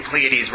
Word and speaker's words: Pleiades: First of all, I hope I'm pronouncing Pleiades right. It sounds right to me. Yes, Shana? Okay.